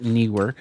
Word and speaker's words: Neewer: And that, yeah, that is how you pronounce it, Neewer.